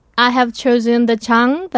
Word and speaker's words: 장: Today's Chinese character is 장.